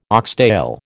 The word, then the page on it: oxtail: American English